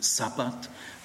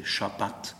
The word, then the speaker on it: Schabbat: Sag bitte nicht Sabbat, immer betonen auf der zweiten Silbe, Schabbat. Sagt bitte nicht Tora, sondern es heißt Torah.